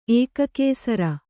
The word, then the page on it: ಏಕಾಕ್ಷರ: ēkākṣara